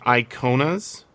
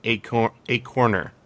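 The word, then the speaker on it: ikonas: It looks like Iconas, but it's actually pronounced more like a corner. And so when you get the line, turn out the chronic a corner, you can kind of rhyme the chronic with iconic, right? Iconic, iconer.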